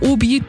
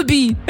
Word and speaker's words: Obi: Und das ist keine Abkürzung, aber Obi, das ist einfach nur Hobby auf Französisch ausgesprochen. – Oh. – Obi.